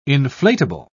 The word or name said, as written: inflatable